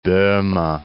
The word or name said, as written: Bohme